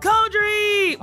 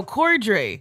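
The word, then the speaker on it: Corddry: It's Rob Coldry. Wait, did I say your... No, Coldry. – Coldry, yeah, you said it right.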